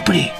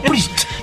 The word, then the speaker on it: Priest: Lo que pasa es que diciendo Pri solo. – Judas Pri. – Parece el partido mexicano. – Claro, como lo pronuncia. – Pero Priest. – Priest. – Ahí está con la T.